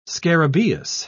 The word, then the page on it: scarabaeus: British English